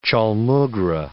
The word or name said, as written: chaulmoogra